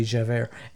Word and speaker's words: Javert: But this police officer by the name of Javert, I guess it's Javert, it's J-A-V-E-R-T, that's French, right? So be Javert.